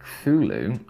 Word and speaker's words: Cthulhu: And the first syllable is not unlike clull in sound, clull. Hence the H represents the guttural thickness. Clulu. Clulu.